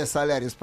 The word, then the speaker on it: Solaris: И выкатывают оранжевый Hyundai, Hyundai Solaris, правильно? – Hyundai, Hyundai, Hyundai запомните. Да, да, Hyundai Solaris, ударение на первом слоге.